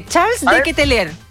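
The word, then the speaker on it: Charles De Ketelaere: Lo dije así, mira, dije Charles de Ketelaer. – Perfecto. Bien. Extraordinaria tu pronunciación del belga.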